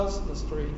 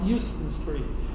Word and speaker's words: Houston: Jews call this New York, the same way that they call a certain street on the Lower East Side a Houston Street and not Houston Street.